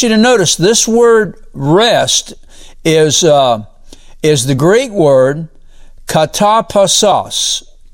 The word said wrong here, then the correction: katapausis: Katapausis. That's how you go. That's how you pronounce it.